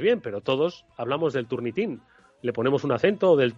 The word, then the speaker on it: Turnitin: Sí, bien, de entrada yo eh, lo españolizaría y lo llamaría turnitin porque bien, es una cuestión de, acento, básicamente, ¿no? Los americanos ya dicen más turnitin y bueno,